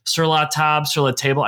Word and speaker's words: Sur La Table: You were the national chef at Sur La Table.